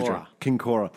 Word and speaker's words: Kinkora: Kincora.